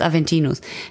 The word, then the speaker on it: Aventinus: Aventinius. – Kriegt von dir? – Ach nee, ich dachte gerade, der heißt Aventinius und ich habe das falsch gesagt. Der heißt Aventinus.